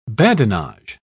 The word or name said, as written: badinage